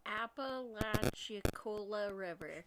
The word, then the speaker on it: Apalachicola: How do you say that word? – Apalachicola River. – Thank you.